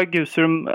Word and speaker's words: Gusum: Gusum, med risk för att jag uttalar det helt fel, den nämndes aldrig. Är, det typiskt och är det ett sånt förbiseende som är en anledning till att du skrivit boken? – Eh, ja, det kan det på sätt och vis sägas vara och du uttalar Gusum alldeles rätt.